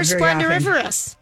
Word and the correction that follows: splendiferous: I don't know that you said it right. – Of course I didn't. Splendiferous.